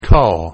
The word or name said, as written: call